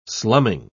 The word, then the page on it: slumming: British English